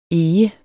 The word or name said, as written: EGERNE